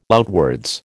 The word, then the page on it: outwards: American English